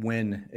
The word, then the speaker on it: Nguyen: Trung is not, but the last name Nguyen is a common last name. – And you Nguyen, you pronounce it W-Win, W-I-N.